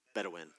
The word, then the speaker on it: Bedouin: I want to show you a picture of the desert that I believe it's Boudin is how you say it, or Boudin people. These are the people that live in the wilderness that you'll see here. Bedouin, thank you.